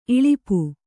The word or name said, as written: ಇಳುಪು